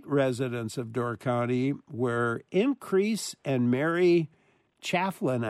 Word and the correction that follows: Claflin: I think that's pronounced right. – Claflin, yes. – Claflin? Oh, yes. Okay. It seemed like a weird spelling, so Claflin.